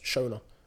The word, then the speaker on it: Seonaid: No, Sinead, that's actually, so S-E-O-N-A-I-D is how my sister's name is spelled. Sinead, it's actually pronounced Shona. – And then there's S-I-N-E-A-D.